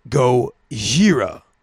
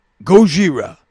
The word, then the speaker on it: Gojira: That's actually pronounced Gojira. Gojira. And I know I sound like a Japanese guy saying Godzilla. Gojira. Gojira. But actually, Gojira is the Japanese word for Godzilla.